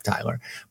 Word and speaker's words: Tylor: It's just Tyler McGill, but I understand why people pronounce it like that because they decided to spell Tyler, T-Y-L-O-R. But it's just straight up Tyler.